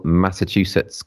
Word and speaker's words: Massachusetts: In a rapidly changing world, four teenagers come of age in a small Massachusetts coastal town.